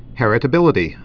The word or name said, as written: heritability